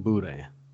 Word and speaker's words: boudin: By the way, you don't pronounce the N. – Well, you don't pronounce the N, but I say boudin. And I can't say it? – Boudin. – How do you say it? – Boudin. – Boudin? – You just got to say eh.